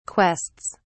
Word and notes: quests: British English

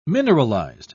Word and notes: mineralized: British English

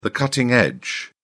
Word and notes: cutting-edge: British English

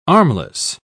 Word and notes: armless: British English

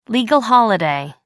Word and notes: legal holiday: American English

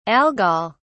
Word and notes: algol: British English

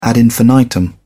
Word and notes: ad infinitum: British English

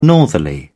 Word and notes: northerly: British English

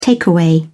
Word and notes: takeaway: British English

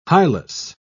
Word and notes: hilus: British English

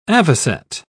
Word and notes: avocet: British English

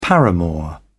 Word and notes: paramour: British English

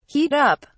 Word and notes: heat up: British English

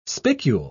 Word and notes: spicule: British English

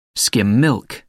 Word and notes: skim milk: American English

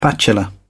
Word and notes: bachelor: British English

